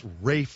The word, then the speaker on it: Ralph: What's this Rafe Fine? Can somebody help me with that? What is that? That's not how you spell it, man. You spell it R-A-L-P-H. And if you say Ralph, people look at you like, ooh, don't you know? No, I don't know. What's wrong with Ralph Fiennes or Fine or however the hell you want to be?